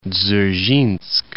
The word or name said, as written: Dzerzhinsk